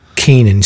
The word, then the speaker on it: Canaan: So Saturday, January 20th, at 8:40 p.m., is it Canaan? How do you pronounce it? – I believe it's Canaan. – Canaan. C-A-N-A-A-N.